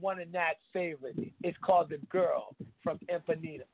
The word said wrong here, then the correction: Ipanema: Did I say that right? – Upanema. – Did I say that? – Upanema. The girl from Upanema.